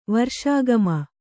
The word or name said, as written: ವರ್ಷಾಗಮ